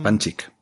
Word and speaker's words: Panchik: Scott, Scott Pancake, este tenía una... – Panchik. creo que es Panchik. creo que en inglés lo pronuncian Panchik. Está, estaba pensando cómo lo pronunciaba, pero creo que es Scott Panchik.